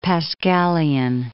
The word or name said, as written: Pascalian